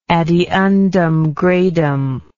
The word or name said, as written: ad eundem gradum